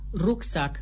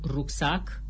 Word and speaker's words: rucsac: după ce repetă asemeni altor persoane știutoare că se spune corect caracter, nu caracter, rucsac, nu rucsac, simbol, nu simbol, Oana Nicolae dă lovitura de grație.